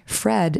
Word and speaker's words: Phread: And it's called Formerly Fred, which formerly is spelled traditionally F-O-R-M-E-R-L-Y. Fred is spelled P-H-R-E-A-D, like bread.